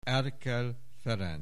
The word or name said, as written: Erkel Ferenc